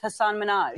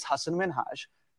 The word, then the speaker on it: Hasan Minhaj: Everyone that says your name says Hasan Minhaj. – Yeah. But the real way you pronounce it, and this is a big deal because my parents are here, it's Hasan Minhaj. And people always mispronounce it. They're always like, Hasan Minhaj.